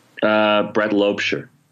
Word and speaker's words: Brett Loebscher: I'm going to ask firstly, how are the Americans pronouncing Brett's name? – Brett Loebscher. They know what to do.